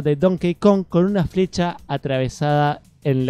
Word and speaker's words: Donkey Kong: Me gusta que ya pronuncias bien Donkey Kong.